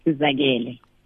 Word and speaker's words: Sizakele: Sizakele or Sizakele? – Sizakele. – Sizakele. – Yes.